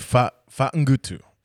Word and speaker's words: Fagutu: Your husband told me how to say it. Okay. He said Fa-ngutu. – Fa-ngutu. – Fa-ngutu. – Wait, you don't even say the G? – No, it's very soft.